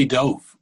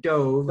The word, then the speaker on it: Dov: We have a good friend named Dov, actually, but he pronounced it Dov. You pronounce it Dov.